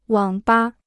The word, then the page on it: 网吧: wǎng bā